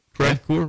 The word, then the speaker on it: Crevecoeur: How do you say that? Crevecoeur. Okay.